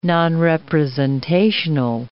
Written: nonrepresentational

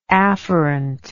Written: afferently

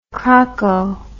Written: crocko

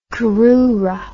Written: crura

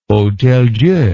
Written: Hôtel-Dieu

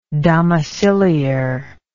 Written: domiciliar

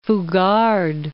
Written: Fugard

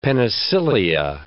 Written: penicillia

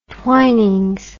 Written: twinings

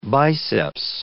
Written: biceps

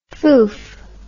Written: foof

